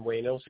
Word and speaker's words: Ueno: My name is Shin Ueno, spelled S-H-I-N. Last name is spelled U-E-N-O.